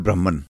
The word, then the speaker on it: Brahman: This supreme entity is called Brahman, pronounced as Brahma. We will depart from the tradition and simply spell it as B-R-A-H-M Brahma to maintain the correctness of the pronunciation.